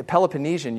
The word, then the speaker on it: Peloponnesian: and it was allied with Sparta during the Peloponnesian. Peloponnesian, you do say it that way.